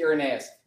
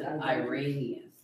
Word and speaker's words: Irenaeus: Does anybody know who Irenaeus is? Yeah, okay, exactly. That's what I expected. Irenaeus. Everybody say Irenaeus.